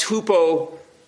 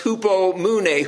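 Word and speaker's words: hupomune (ὑπομονή): And maybe you remember the word in the Greek is pronounced hupomune. Well, actually, you probably don't remember that. Who would remember the Greek unless you're a Greek scholar? But the word is pronounced hupomune.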